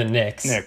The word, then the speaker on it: Knicks: But the Jazz started off with their revenge game against the Nuggets, which was the last team to beat the Knicks. It's the silent K and then the N sound.